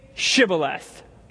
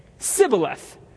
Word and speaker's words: Shibboleth: And when he said no, they said to him, then say Shibboleth. And he would say Sibboleth, for he could not pronounce it right.